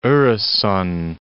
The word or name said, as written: Oresund